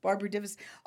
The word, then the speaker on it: Barbara Divisek: And I'd call, and it's Barbara Divisek.